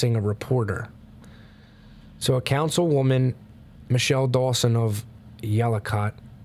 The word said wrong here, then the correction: Yacolt: I'm probably saying it wrong. Yacolt, Yacolt, Washington,